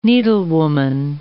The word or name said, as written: needlewoman